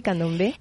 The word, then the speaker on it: candombe: ¿Se dice así, candombe? – En realidad es candombe.